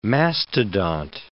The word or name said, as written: mastodont